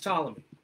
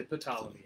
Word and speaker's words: Ptolemy: The king, Ptolemy, he's spelled with a P. – P. Ptolemy. It's pronounced Ptolemy. Sometimes people say Ptolemy, and I hate that.